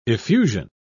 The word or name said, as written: effusion